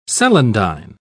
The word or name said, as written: celandine